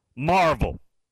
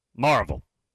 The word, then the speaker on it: Maryville: The airport on Rocky Top is over there in Marvel. That's Maryville, but pronounced Marvel. Marvel.